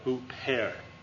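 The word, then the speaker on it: Huper (ὑπέρ): And this is the rough breathing mark. How's that pronounced? Yeah. So it sounds that. Huper.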